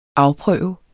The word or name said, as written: afprøve